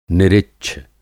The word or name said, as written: निरिच्छ